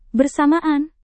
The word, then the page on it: bersamaan: indonesian